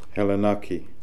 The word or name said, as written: Hellenaki